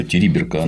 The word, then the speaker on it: Териберка: Что группа французских журналистов решила, посмотрев, решила немедленно помчаться в эту териберку. Я все время ударение неправильно ставлю. Териберка, она, по-моему, посмотреть, так ли там все на самом деле.